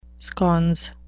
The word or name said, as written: sconce